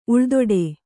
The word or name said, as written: ಉಳ್ದೊಡೆ